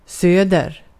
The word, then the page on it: söder: /søːder/